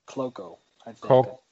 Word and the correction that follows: Cuoco: It's C U O C O. Cuoco.